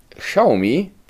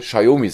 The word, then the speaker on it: Xiaomi: Dieses Xiaomi ist ganz offiziell von Xiaomi selbst in, ins Leben gerufen worden für ähm, Europa, Deutschland. Weil sie sagen, dieses Xiaomi, wie es in China wirklich ausgesprochen wird, kann keiner, also sagen, dürft ihr Xiaomi sagen.